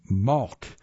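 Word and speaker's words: Mahlke: Pen plus Pillar, the High Point stationery business run by, I think I pronounced the name wrong. It's Malk, I think, M-A-H-L-K-E. I thought that was an I there.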